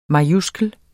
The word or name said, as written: majuskel